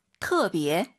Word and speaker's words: 特别: 근데 발음하실 때 터 아니고요. 트어라고 보시면 됩니다. – 特别.